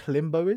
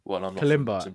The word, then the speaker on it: Kalimba: Kalimba.